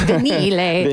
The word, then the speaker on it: vinile: Sorry, I said it wrong. I said vinyl. It's vinile.